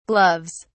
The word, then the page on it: gloves: British English